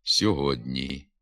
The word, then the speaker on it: сьогодні: Наприклад, пишемо «сьогодні», а вимовляти треба «сьогодні». Передньоязиковий приголосний «д» стоїть перед пом'якшеним передньоязиковим «н» і тому сам м'якшиться «сьогодні».